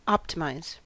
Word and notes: optimize: American English

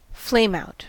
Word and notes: flameout: American English

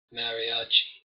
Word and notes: mariachi: American English

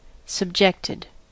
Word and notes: subjected: American English

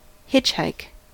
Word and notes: hitchhike: American English

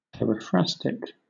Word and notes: periphrastic: British English

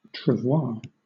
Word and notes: travois: British English

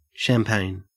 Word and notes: champagne: Australian English

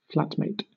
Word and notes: flatmate: British English

